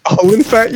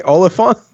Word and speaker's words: Olyphant: Olyphant? You mean Olyphant? – Timothy Olyphant. It says Phant. Olyphant. Olyphant of the Opera. You know, spelled like that.